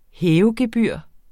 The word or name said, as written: hævegebyr